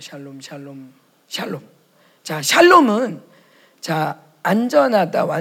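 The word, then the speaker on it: 샬롬 (שלום): 그 샬롬 그 미음자가 실제로 미음 발음이 나요.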